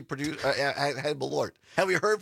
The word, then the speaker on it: Malort: So Malort and somebody, Julie or Jules in Mosinee, listening on WXCO, says, are you sure it's not pronounced Merlot? I actually looked it up, Jules, because I didn't know, and that's why I found that video of this guy reviewing it. And no, it's spelled differently than Merlot. It's M-A-L-O-R-T, and it's pronounced Malort.